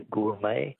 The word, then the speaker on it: gourmet: Words that end with a silent T. Beret is B-E-R-E-T. Which word for a connoisseur comes from an old French word meaning wine taster? – Be a gourmet. – Gourmet, yes. Correct. G-O-U-R-M-E-T.